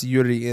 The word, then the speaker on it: Yuri: It is Yuri in it's pronounced Yuri in Japanese.